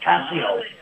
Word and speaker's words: Casio: And I have a, I don't know if I'm pronouncing this right, but Casio, I guess this is the right pronunciation.